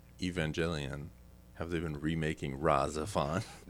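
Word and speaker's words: Rahxephon: It's called Razzephon. – Razzephon, like, okay. R-A-H space then Z-E P-H-O-N, I think that's how it's spelled.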